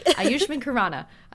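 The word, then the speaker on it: Ayushmann Khurrana: Ayushman Khurana. – You got it. – Ayushman Khurana.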